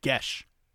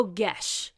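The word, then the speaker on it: geas: And they just kind of jump back into this world, and this is where you talked about the spell called G-E-A-S. G-E-A-S. – It's actually pronounced Gesh. – Oh, Gesh. – It's it's an Irish term.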